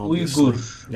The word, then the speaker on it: Uyghurs: Uyghurs, however I should pronounce that, the minority in the Muslim minority in China. – Uyghurs.